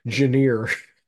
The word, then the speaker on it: genre: Stephen Minskoff taught us that the correct spelling or the correct pronunciation of that word is Janeer.